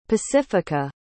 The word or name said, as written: pacifica